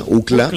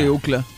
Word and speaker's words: Ookla: Creo que así se pronuncia, Ookla. – Sí, Ookla.